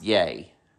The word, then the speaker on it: Ye: He is the artist formerly known as Kanye West, but now he just goes by Y-E, and I think it's pronounced Yay, you know, just the last two letters of his first name.